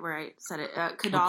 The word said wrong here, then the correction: Collodi: Carlo Collodi. – Thank you, Collodi. – I think it's how you say it.